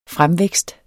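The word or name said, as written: FREMVÆKST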